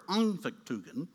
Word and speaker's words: Anfechtungen: When we, in our godlike understanding, collide with the real world, we hit a feeling of despair, what Martin Luther called Anfechtungen. Did I say that right, Philip? Thank you. I had to get Philip to coach me on this. This is a German word, Anfechtungen.